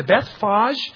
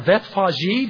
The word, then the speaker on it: Bethphage: When they had approached Jerusalem and had come to Bethphage, or Bethphage, depending on your pronunciation,